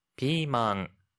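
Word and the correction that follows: 피망 (ピーマン): 피망, 피망인데요.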